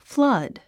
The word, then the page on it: flood: flʌ’d; フラァド